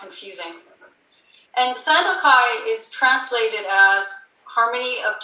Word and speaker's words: Sandokai: And just to review, the word Sandokai is made up of three syllables.